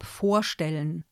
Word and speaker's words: vorstellen: Also zum Beispiel, dass das Verb vorstellen auf der Vorsilbe vor betont wird.